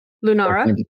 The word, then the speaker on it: Lunara: Is there an easy way to say it? – Lunara. – Lunara. All right. Thank you for pronouncing it for me so I can hear it.